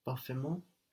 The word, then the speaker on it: parfaitement: We have a silent T in parfait. Will it still be silent when we add Parfaitement.